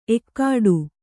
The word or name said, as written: ಎಕ್ಕಡಿ